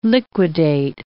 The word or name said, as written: liquidate